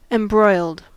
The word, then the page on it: embroiled: American English